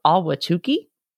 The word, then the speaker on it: Ahwatukee: I actually kind of Googled how to say that, and that's how it's pronounced as far as I can find out, but it's spelled A-H-W-A-T-U-K-E-E, Ahwatukee.